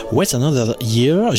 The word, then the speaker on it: What's Another Year: What's Another Year, j'espère que je l'ai bien prononcé, c'est Johnny Logan.